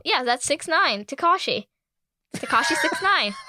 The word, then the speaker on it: 6ix9ine: Yeah, 6ix9ine. – 6ix9ine? There's so many more letters and numbers in his name. – I mean, I just know that's how you say it.